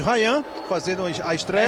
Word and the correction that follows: Ryan: É Rian, viu, Rian. Alex? Rian mesmo. Ok. É porque é com Y, né? Eu aprendi que era diferente a pronúncia, né?